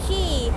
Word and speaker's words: Quay: คีย์